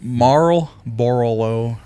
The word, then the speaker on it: Marlboro: Marlboro Red. I don't know if I said that right. – You did. – Marlboro Red.